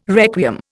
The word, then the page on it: requiem: Indian